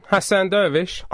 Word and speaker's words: Hassan Dervish: I think I pronounced your name wrong. – No, you were spot on. Hassan Dervish.